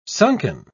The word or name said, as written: sunken